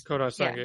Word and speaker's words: Kodai Senga: A couple of our listeners have commented, I'm going to butcher this name too, Kodai Senga with the Mets. – Kodai Senga, yep.